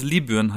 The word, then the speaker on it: Libyen: Aber das ist ja wie, wie wenn man sagt, äh, äh, äh Also korrekt ausgesprochen ja. würde es Libyen heißen.